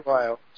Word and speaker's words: Weil: Andrew Weil. – Yeah, W-E-I-L, famous, you know, well-known person, talks about alternative health all the time.